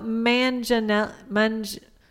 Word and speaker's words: Manganiello: Yeah, how do you say that gentleman's last name? – Manganiello? – Manganiello? Is that correct? I don't know that that's correct either. – It's pronounced Jaguarde or whatever Joey's last name is. – It's pronounced Tony Danza. – It's Joe Manganiello.